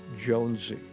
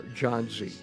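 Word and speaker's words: Jónsi: The lead singer for Sigur Rós goes by the name Jonesy. John Z, depending on how you want to take a look at the pronunciation.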